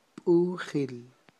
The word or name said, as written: b'ujil